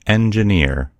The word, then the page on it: engineer: American English